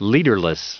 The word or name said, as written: leaderless